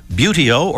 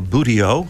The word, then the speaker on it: Buteo: Just look for Buteo or Buteo, however you like to pronounce it, B-U-T-E-O, Buteo Inspirations.